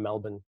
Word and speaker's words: Melbourne: So I remember you said once on one of the webinars, how do you pronounce Melbourne? Is it like Jason Bourne? And I said, no, it's like Bourne, but it's actually more like bun. So I said I'm from Melbourne. So it's almost like B-U-N, bun, like you'd get at a bakery, but with Melbourne.